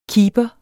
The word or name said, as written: KEEPEREN